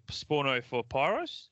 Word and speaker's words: Sporno for Pyros: He was all across the name, rhyming with the band Porno for Pyros.